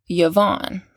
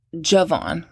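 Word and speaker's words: Javan: So, and then we have Yavon, or you might pronounce it Javon, it's with a J.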